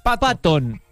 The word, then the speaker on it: Patón: Ahora, el tema, Orieta, no lleva acento en la O. Por lo tanto, entiendo que es Patón. Patón, claro.